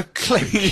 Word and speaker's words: clique: Well, in the US, it's a clique. – At this moment, I take particular pleasure. I have made two mispronunciations, one in last show and one this evening, off air. – Do me a favour, John. Look out the window behind you. – Huh? – you look out the window behind you? – I don't think so. Yeah. – Can you see the stars and stripes? No. You're in England. It's a click. – Anyway. – Click. Anyway, moving on.